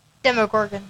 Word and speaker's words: Demogorgon: Demogorgon, yes. – Yeah, Demogorgon. Right, looking around, you see that there's nobody else on campus. Everybody has suddenly disappeared. You jump and run out the door with the Demogorgon chasing after you, but there's nowhere to go.